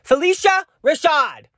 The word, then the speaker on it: Phylicia Rashad: Oh, Phylicia Rashad.